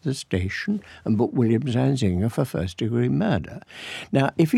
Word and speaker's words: Zanzinger: Zanzinger is stressed on the second syllable, but not on the final syllable.